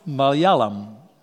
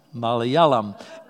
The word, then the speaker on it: Malayalam: How would you pronounce it? Malayalam. Malayalam. Malayalam. It's a palindrome. You can read it backwards and forwards, and it still says Malayalam.